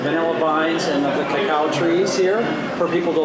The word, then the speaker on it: cacao: Yes, the cocoa is, we pronounce it here in Mexico, cacao.